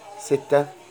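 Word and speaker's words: sept: أول رقم صفر اسمه زيغو يعني صفر، واحد يعني أن، اثنين، دو، ثلاثة، تغوا، أربعة، كاتغ، خمسة، سانك، ستة، سيز، ستة، سيز، سبعة، ست، البي لا تنطق، ست،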